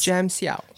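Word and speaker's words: Jam Hsiao: But yeah, so there was a Taiwanese singer named, I'm going to butcher his name. Was it Jams? – Jam Siao.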